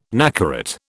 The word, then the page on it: nacarat: British English